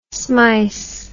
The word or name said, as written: smice